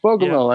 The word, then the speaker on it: Bogomil: Earlier in our conversation, you brought up Lieutenant Bogomil. Am I saying that right? – Bogomil, yeah.